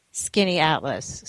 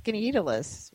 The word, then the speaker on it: Skaneateles: So it's Skaneateles. – See? So most people think it's Skaneateles, right? Not Skaneateles. That's, I made that mistake until the last day that I thought, no, now I know how to pronounce it.